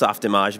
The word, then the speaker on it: Softimage: Well, first, it's pronounced Softimage because it's a French-Canadian company, so it's really just Soft Image if you spell it out.